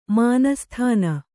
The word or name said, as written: ಮಾನಸ್ಥಾನ